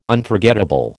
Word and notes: Unforgettable: American English

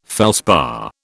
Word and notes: felspar: British English

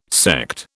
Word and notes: sect: British English

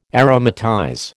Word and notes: aromatize: American English

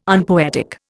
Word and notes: unpoetic: Indian

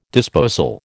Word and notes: disposal: American English

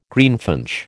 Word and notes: greenfinch: American English

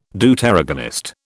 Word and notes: Deuteragonist: British English

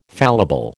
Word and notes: fallible: American English

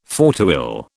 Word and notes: fauteuil: British English

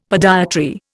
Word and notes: podiatry: Indian

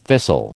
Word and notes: thistle: American English

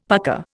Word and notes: pucka: Indian

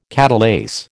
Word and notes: catalase: American English

